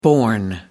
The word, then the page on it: born: American English